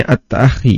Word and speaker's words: at-ta'akhi: Di antara cara Nabi sallallahu alaihi wasallam dalam melakukan at-ta'akhi. Di sini bacanya at-ta'akhi ya, mempersaudarakan adalah dengan melihat latar belakang masing-masing sahabat.